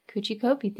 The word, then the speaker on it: Kuchi Kopi: It's spelled K-U-C-H-I-K-O-P-I. So, you know, there were a lot of ways I could have gotten this wrong and I got it right.